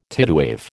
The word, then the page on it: tidewave: American English